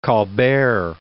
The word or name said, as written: Colbert